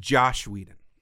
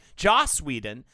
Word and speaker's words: Joss Whedon: I'm sorry, it's pronounced Josh Whedon. – Is it? – No. – I was about to say, I'm like, how am I constantly... fucking pronunciation. Joss Whedon.